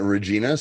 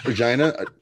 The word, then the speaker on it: Regina: Regina is it's like how you say it's like queen. It means queen.